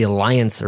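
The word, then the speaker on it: Allianz: Okay, so I have a small, small game for you in honor of the game, which when we were talking before, you said it was the Aliens. It's the Alliance Arena. They're playing at Bayern Munich. Okay? – Is that how you pronounce that? – I'm pretty sure it's Alliance. – Okay. – I was doing that wrong.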